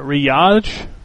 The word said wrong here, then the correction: Riyadh: KSA? – KSA. – R-I-Y-A-D-H. – Riyadh.